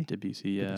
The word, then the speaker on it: Debussy: Claude Debussy? – Debussy, yeah.